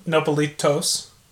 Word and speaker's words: nopalitos: nopalitos.